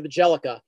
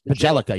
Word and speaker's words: Bjelica: It's Nemanja Bjelica, I think. Bjelica. – Nemanja Bjelica. Yes. – Bjelica. Yes. Okay.